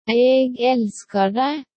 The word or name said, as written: eg elskar deg